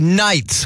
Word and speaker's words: knight: I want to make you my Friday knight with a silent K.